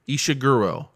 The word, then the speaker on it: Ishiguro: Ishiguro.